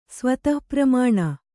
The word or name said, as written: ಸ್ವತಃಪ್ರಮಾಣ